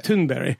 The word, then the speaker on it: Thunberg: It's, if you want to do the fake pronunciations, yeah. Thunberg. It's like a something you need to bury.